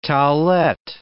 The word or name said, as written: towelette